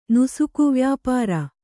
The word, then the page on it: ನುಸುಕುವ್ಯಾಪಾರಿ: kannada; nusuku vyāpāri